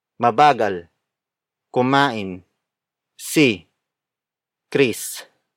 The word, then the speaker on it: kumain: It works as an adverb since it illustrates the action in the sentence, which is kumain. Kumain. Kumain. Kumain is spelled as K-U-M-A-I-N.